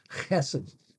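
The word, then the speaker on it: chesed (חסד): I won't pronounce the first one right because you got to get a rasp back in your throat, but chesed. Oftentimes people pronounce it just chesed with an H-E-S-E-D.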